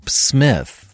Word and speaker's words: Psmith: And so rather than try to enunciate Smith for the audiobook version, I'll add the term the P is silent when it seems appropriate.